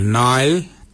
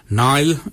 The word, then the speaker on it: ngarly: Wonnil is spelled W-O-N-D-I-L, Wonnil. Your nail is your underarm. Nail is spelled N-G-A-R-L-Y, nail, for underarm.